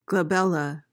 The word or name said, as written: glabella